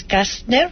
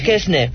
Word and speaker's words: Kästner: Sí, lo que pasa es que la A, vamos a intercalar, lección de pronunciación, la A con dos puntitos en alemán se pronuncia E. – Ajá, por eso ha dicho Kestner.